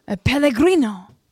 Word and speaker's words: Pellegrino: Pellegrino.